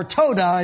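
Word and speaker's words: toda (תודה): The word praise there is tauda. Listen carefully, saint. Your breakthrough hinges on this. Whoso offereth tauda, or toda is the way it's pronounced, glorifies me, and to him that orders his conversation aright will I show the salvation of God.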